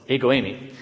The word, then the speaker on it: ego eimi: Oh, it's ego eimi.